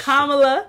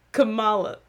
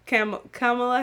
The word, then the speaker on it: Kamala: I'm tired of this Kamala. – Shit. – Her name is Kamala Harris. – Wait, how did I say it? – Kamala. You said Kamala Harris. You said something. It wasn't her name.